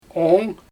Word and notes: oong: Xyzyl

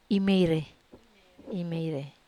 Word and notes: meire: Wamesa

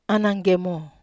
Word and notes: ananggemo: Wamesa

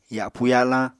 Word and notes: yapu-yalañ: Apatani

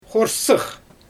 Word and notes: xorsɯx: Xyzyl

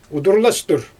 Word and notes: udurlastur: Xyzyl

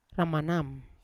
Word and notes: ramanam: Yawa

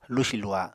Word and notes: lusi-luha: Apatani

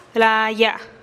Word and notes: laaya: TdVZ

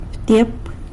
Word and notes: btyep: sanlucasquiavini

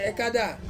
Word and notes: kada: Ansus

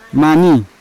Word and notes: mani: Yawa

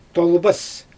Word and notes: tolbas: Xyzyl

